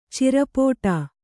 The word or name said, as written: ಚಿರಪೋಟಾ